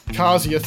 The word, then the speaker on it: Kazia: Today we're talking to Dr. James Garner, the CEO of cancer drug developer Kazia Therapeutics.